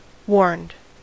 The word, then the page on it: warned: American English